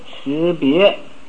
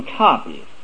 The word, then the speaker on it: 差别: 一切贤圣皆以无为法而有持别。这个持别一般人的读差别，我们应该读成持别，因为不认字的人呢就看这个字是个差，所以就念成一个差了，所以念成一个差就差中差。